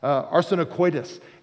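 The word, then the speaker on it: arsenokoitai: And then the second word we see here that's interpreted as sodomites, it's pronounced arsenicoitis. And this is a compound word.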